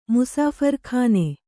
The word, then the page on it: ಮುಸಾಫರ್‍ಖಾನೆ: musāphar khāne